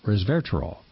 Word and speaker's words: resveratrol: Just phonetically, it's res, R-E-S, ver, V-E-R, atrol, A-T-R-O-L, resveratrol.